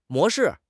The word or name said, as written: 模式